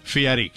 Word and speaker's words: Fieri: Guy Fieri. I guess he... – Love Guy Fieri. – He's... How do you say it? – Fieri.